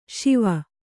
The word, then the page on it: ಶೇವ: śeva